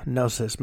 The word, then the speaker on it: gnosis: The name derives from the Greek word gnosis, meaning knowledge. The G is silent. It's G-N-O-S-I-S.